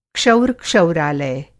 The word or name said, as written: क्षौर-क्षौरालय